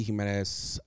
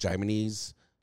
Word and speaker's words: Jimenez: So Rudy Jimenez, I've been called Jimenez.